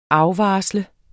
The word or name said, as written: afvarsle